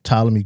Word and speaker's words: Ptolemy: It's like the Life of Ptolemy Gray or something like that. The P is silent. – The P is silent. – Yeah, because it's spelled PTOMLEY, something like that.